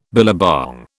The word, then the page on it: billabong: British English